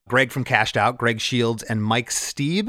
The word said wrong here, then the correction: Steeby: Is that how you pronounce? – Steeby, yeah.